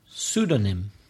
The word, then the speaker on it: pseudonym: Pseudonym. – So what? That's a silent P again at the beginning. – It's the first letter, silent P. – Pseudonym.